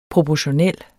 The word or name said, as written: proportionel